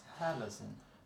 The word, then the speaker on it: Halocene: Och då var det Halloween, tror jag det ska uttalas. – Halloween. – H-A-L-O-C-E-N-E.